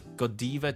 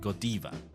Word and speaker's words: Godiva: the first time that I saw this brand, I pronounced it Godiva. – Hmm. Okay? – Why? Because D I V A diva meaning a goddess, right? Yes. And there we go. So Godiva, okay, sounded right to me.